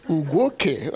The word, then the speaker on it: Ugwuoke: Unlike many, I just say Ugwuoke, but Ugwuoke, that's very correct.